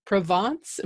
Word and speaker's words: Provence: Okay, Curtis, who comes to us from Provence.